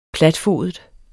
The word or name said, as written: platfodet